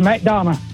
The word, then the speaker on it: McDonough: There's a way to say McDonough, right? – Yes, sir, just McDonough. – McDonough, you got to say it right.